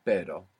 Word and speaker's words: pero: So initial R, or two R's together inside the word, is going to pronounce this double R. So the word for but in Spanish is pero. – Pero. – P-E-R-O. And the word for dog is perro. P-E-R-R-O.